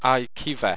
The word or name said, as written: iquiva